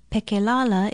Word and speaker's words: Pekelala: Pekelala is spelled P-E-K-E-L-A-L-A, and it means federal.